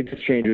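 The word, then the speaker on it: Dischinger: his Terry Deschanger is how you say his name, Indiana native, coincidentally.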